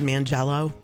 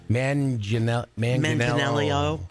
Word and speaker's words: Manganiello: Mangiello? Mangiello. – Like, I know, like, sometimes how to say it, and then I forget. Manganiello.